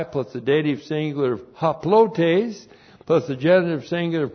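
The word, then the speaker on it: haplotes (ἁπλότης): Haplotes looks like that in the Greek, H-A-P-L-O-T, long E-S, haplotes.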